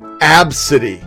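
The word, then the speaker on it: Abcde: It's pronounced Absidy.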